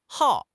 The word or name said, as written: hao4